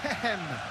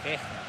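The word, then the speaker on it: Heggem: Creo que bien, pero menos con Hegem. – ¿Yo cómo le digo? – Hegem. – ¿Y es? – Hegem.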